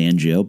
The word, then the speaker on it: Ngo: His name was Jonathan Ngo, and he said that's how you pronounce it. When I was doing the audio book I wrote about Paige, there was some debate on how to properly say that. I say Ngo based on personal experience.